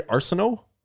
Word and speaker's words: Arsenault: Like, first, just I do want to bring it back to that, but I also want to bring up Vincent Arsenault. Is that how it's said? Sorry, Arsenault. – I believe so.